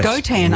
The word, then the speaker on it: Gotan: Gotan. Yes, I'm not sure people pronounce it differently, but Gotan is like a misspelling of tango. It's got all the letters of tango in it.